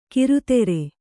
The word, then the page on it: ಕಿರುತೆರೆ: kannada